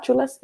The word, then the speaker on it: Xula: Is it Chula? Chula CBD? – Yeah, Chula. It's Xula, but you know, obviously like Chula, like somebody who's cool and chill and cute.